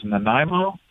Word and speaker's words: Nanaimo: I remember a track, I want to say it was Nanaimo.